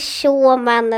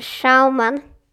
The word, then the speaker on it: Schaumann: W-A-L-T-E-R. Schaumann. S-C-H-A-U-M-A-N. Och man uttalar inte Schaumann eller någonting. Utan Schaumann.